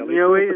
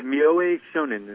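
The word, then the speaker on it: Myōe: Mioe? I'm not sure how to say his name. – Yeah, Mioe is how I've heard it pronounced. Yeah. – Come again? – Yeah, Mioe Shonin is how I've heard it pronounced.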